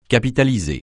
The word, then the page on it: capitaliser: francais